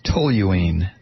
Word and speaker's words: toluene: Bob in Miami says it's pronounced toluene, the organic compound toluene. Yes, he writes, I was a chem major.